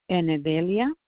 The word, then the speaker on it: Enedelia: My first name is Enedelia, but let me spell it for you. E-N-E-D-E-L-I-A, Garcia.